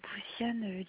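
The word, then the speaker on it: Bouziane: Je suis madame Boussiane Linda et je viens de me rendre compte que j'ai complètement oublié de faire l'enquête de sociale de Domo France.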